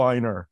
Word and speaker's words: Einar: And I was like, whoa, he's like the evil Einar. – You know, there's actually, my family actually pronounces it Einar, sort of like reindeer, right? – Einar, okay.